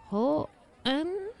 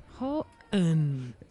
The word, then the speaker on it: Houghton: Houghton? – That's right. – Houghton.